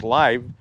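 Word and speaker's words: live (לב): You know, thinking about this and about heart in the Bible, the Hebrew word for heart is lev, pronounced live.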